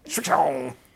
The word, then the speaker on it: Srixon: How do you say it? – Srixon. Srixon.